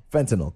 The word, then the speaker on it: fentanyl: Fentanyl.